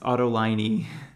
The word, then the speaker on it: Autolinee: You're throwing an A at the end, I think. – No, Linea. – Linea. – No, Linea. L-I-N-E-A, right? – No, L-I-N-E-E. – That's how the word is spelled? – Yeah. It's Autolinee.